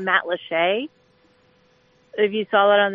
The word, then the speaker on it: Matlacha: It's spelled Matlacha, but It's pronounced Matlacheay.